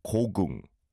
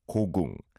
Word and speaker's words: 고궁: It's two syllables, 고궁, 고궁.